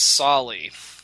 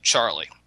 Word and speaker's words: Tsali: It's a man named Solly, T-S-A-L-I, Solly. Americans at the time pronounced it Charlie. Anyway, there were because it was a hard word to say.